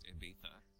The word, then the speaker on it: Ibiza: I think it's pronounced Ibiza.